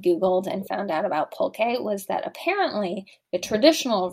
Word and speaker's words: pulque: Well, It's pronounced pulque, as it turns out. We've probably mispronounced it in the past.